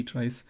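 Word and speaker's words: trie: And he also did concurrent lock-free data structures, which is an idea that is implemented in Scala before, called C trees. I think they're still pronounced as trees, but written as T-R-I-E-S, I think. – It's very confusing. – I think it's a tree, but with I-E.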